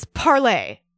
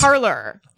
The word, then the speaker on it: Parler: So they're using a platform that's named after a French verb and the way that you pronounce that is parlay not yes. parlor.